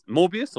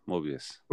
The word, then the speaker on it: Morbius: And Mobius must, it's Morbius or Mobius? Morbius.